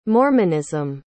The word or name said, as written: mormonism